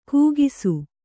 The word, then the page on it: ಕೂಗಿಸು: kūgisu